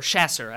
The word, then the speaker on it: chasseur: Chaucers, or chasseur, I guess is a more accurate pronunciation.